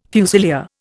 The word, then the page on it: fusilier: Indian